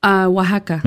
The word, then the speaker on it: Oaxaca: Is that the way you pronounce it? – Oaxaca.